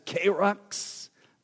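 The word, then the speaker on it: kerux (κῆρυξ): So I'm going to start with first of all with the first word, which is pronounced somewhat as kerux.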